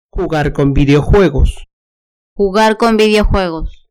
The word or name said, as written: jugar con videojuegos